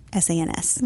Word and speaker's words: sans: S A N S. Maybe I'm saying it wrong. No, that's correct sans gluten.